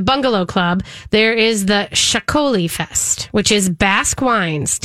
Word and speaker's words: Txakoli: Chacoli, which is spelled T-X-A-K-O-L-I, in case you're wondering and see it and try to figure that one out, is the Basque wine that has got a little bit of sparkle to it.